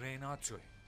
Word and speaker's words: Renato: Renato! – تلفظ درستش رناتو.